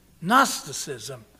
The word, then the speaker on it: Gnosticism: And it's spelled as it sounds, with a G, you know. Gnosticism. And our Christian word know comes from it. And you know, the word know sounds like it starts with a K, right? And so in both of these forms, the initial letter is silent.